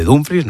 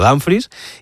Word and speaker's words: Dumfries: Está ya Dumfries en Madrid, que él mismo ha dicho que se dice así, se dice Dumfries, no Dumfries, y el club trabaja en cerrar la llegada de Diomandé y en iniciar las conversaciones con el Manchester City por Rodri.